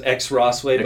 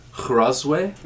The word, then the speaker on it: Crossway: Yeah, so you might pronounce it X Rossway, but we don't. – Yeah, so it's with an X and then Rossway, but it's pronounced Crossway.